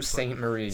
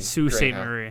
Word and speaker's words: Sault Ste. Marie: I suck at pronouncing Sault Ste. Marie. – Sault Ste. Marie.